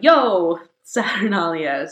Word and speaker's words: Io Saturnalia: So during this time, you would exclaim, "Io Saturnalia," or if you want to use the Latin pronunciation, it would be more of a "Yo Saturnalia."